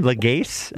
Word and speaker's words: Lagasse: And he how did he pronounce his name? – Lagasse.